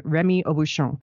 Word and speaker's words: Rémy Aubuchon: He's French-American, but since he grew up in the U.S., I'm going to pronounce his name Rémy Aubuchon.